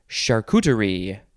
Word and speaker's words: charcuterie: Next is charcuterie. This is kind of, you know, it's a French word. It doesn't necessarily look like it's pronounced. C-H-A-R-C-U-T-E-R-I-E, charcuterie.